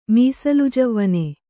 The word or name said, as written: ಮೀಸಲುಜವ್ವನೆ